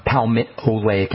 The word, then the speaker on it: palmitoleic: That's one of the neatest benefits of palmitoleic acid. Palmitoleic is spelled P-A-L-P-A-L-M-O-L-E-I-C, palmitoleic acid.